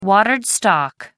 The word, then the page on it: watered stock: American English